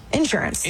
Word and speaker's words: insurance: Health, dental, I-N-S-U-R-A-N-C-E. – Insurance. – Oh, insurance. – Insurance. – Insurance. – When I went to Boston, people said I said that wrong. Insurance. We put the emphasis on the N.